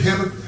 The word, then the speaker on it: Habakkuk: Let's turn to Habakkuk. I can't ever pronounce this. H-A-B.